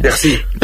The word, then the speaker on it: Piercy: Mujer al borde del tiempo, de Marge Piercy, o Piercy, no sé muy bien cómo se pronuncia. – Piercy, Piercy, Piercy. Piercy, muy rápido.